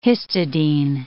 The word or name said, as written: histidine